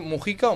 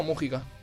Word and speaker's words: Mujica: Rápidamente, el pasado domingo, el programa Salvados volvió a traer a nuestras pantallas a José Mujica, que os pido asesoramiento para pronunciar Mujica o Mújica. – Yo digo Mujica. – Mujica. Pero no tiene tilde. – Ya, pero lo decimos mal.